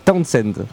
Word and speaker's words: Townsend: Alors j'ai très mal prononcé au début, donc c'est Townsend. – Townsend. – Ouais, t'as mal prononcé.